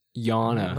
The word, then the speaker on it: Jana: It was Jana? – Yana. – Yana. – Because he was, because Tim was sending us videos. Yeah, you're pronouncing it like Yana. – You're like Yana, like Swedish.